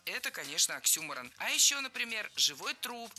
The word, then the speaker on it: Оксюморон: Оксюморон. – Оксюморон. Ударение в этом слове нужно ставить на второй слог. Оксюморон.